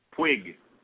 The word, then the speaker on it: Puig: And your other guy, outfielder, Pyong? Did I say that right? – Pig. – Pyong? – Puig. – Puig, like twig, but with a P.